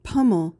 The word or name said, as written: pummel